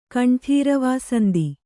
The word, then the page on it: ಕಂಠೀರವಾಸಂದಿ: kaṇṭhīravāsandi